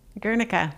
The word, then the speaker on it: Guernica: Am I saying that right? – Guernica. Yes.